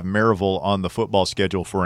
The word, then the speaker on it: Maryville: I believe it's Maryville. – It's Maryville. – The way it's pronounced.